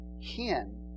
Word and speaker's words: hen: It's hen, H-E-N, hen.